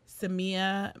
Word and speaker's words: Samia: So my new Instagram is Samia Minnicks Photography.